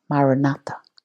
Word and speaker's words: Maranatha: Say this ancient Aramaic word to yourself, Maranatha, 4 equally stressed syllables which mean, "Come, Lord Jesus."